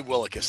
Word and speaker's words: Willekes: I don't know if I'm pronouncing this correctly, but it is Kenny Willekes. – Willekes? – God darn it. – Kenny Willekes. That's right.